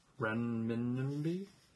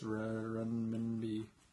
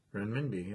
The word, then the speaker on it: Renminbi: Yes, I didn't say it correctly, but that's exactly what it is. It's Renminbi. Right? Is that what it says? – Let's see. Renminbi, yeah.